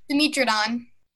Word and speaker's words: Dimetrodon: Let's go around, and say how we pronounce this creature. – Dimetrodon. – Dimetrodon. – Dimetrodon. – Dimetrodon. – Dimetrodon. Dimetrodon. I think it's probably safe to do it any other way. This is another easy name.